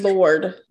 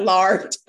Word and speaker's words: Gaylord: How do you say Lord? – Lord. – Not lard. That is something else entirely.